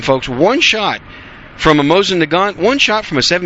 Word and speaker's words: Mosin-Nagant: I think the correct pronunciation is Mosin-Nagant.